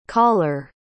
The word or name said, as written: koller